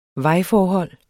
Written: vejforhold

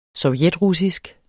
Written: sovjetrussisk

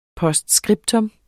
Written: POSTSCRIPTA

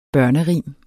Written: børnerim

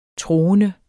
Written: troende

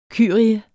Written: kyrie

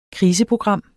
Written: KRISEPROGRAM

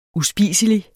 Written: uspiselig

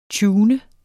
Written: tuner

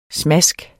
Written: smask

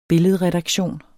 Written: billedredaktion

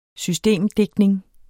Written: systemdigtning

